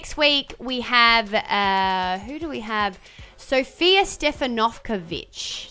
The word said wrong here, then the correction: Stefanovic: Is that how I say her name? Stefanovkovic. – No, Stefanovic. – Ah, there you go. – Because it's the emphasis that wasn't like Karl Stefanovic's last name, because that's been Aussieified. – She is a writer, that's right, who writes a story and a book called Miss Ex Yugoslavia.